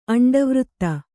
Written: ಅಂಡವೃತ್ತ

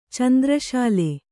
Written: ಚಂದ್ರಶಾಲೆ

